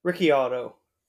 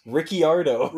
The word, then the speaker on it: Ricciardo: But she was pronouncing Daniel Ricciardo as Ricciardo about a dozen times in the race. – Ricciardo. – Ricciardo, I think is how she pronounced it.